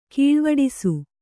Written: ಕೀಳ್ವಡಿಸು

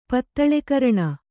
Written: ಪತ್ತಳೆಕರಣ

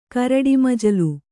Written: ಕರಡಿಮಜಲು

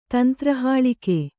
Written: ತಂತ್ರಹಾಳಿಕೆ

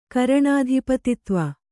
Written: ಕರಣಾಧಿಪತಿತ್ವ

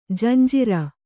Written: ಜಂಝಾರ

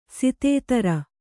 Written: ಶತಧಾರ

